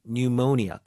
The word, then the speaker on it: pneumonia: pneumonia 앞에 P는 묵음입니다. 그래서 pneumonia, 급성 폐렴 증상으로 갑작스럽게 세상을 등지셨다.